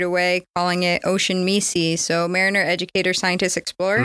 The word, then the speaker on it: MESE: We'll go back to your, it's called M-E-S-E. – Yep, Messi. – Oh, you pronounce it that way.